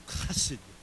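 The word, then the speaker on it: Chesed (חסד): The word used there in the Hebrew language that's translated loving kindness is hesed. To say it properly, you almost have to spit. Chesed.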